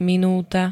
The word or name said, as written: minúta